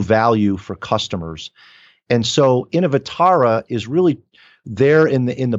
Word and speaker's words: Innovatara: And Innovatara, if I'm pronouncing her name correctly. Tell us about those two. – Yeah, you pronounced her name perfectly. And so Innovatara, we wanted someone to represent the idea of innovation.